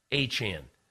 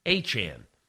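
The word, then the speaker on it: Achane: I said Achan. He goes, no, Achan. I said, Achan? He said, Achan.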